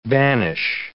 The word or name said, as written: banish